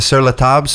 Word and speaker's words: Sur La Table: My girlfriend got me a gift certificate to Sur La Table, so we could.